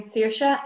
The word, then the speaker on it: Saiorse: Hello, I'm Saiorse, and yes, he did say it right.